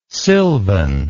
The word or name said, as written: silvan